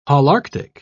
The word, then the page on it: holarctic: British English